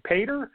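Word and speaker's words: Petr: But there's no way he's beating Peter Yan, man. I mean, listen, maybe 10 years ago, but not right now. I think it's pronounced Pater. So Peter Yan.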